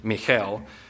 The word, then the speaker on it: Michal: Now as the ark of the Lord came into the city of David, Michael, I'm going to call her Michael, I know it's pronounced probably Michal, but Michael, Saul's daughter, looked through a window and saw King David leaping and whirling before the Lord, and she despised him in her heart.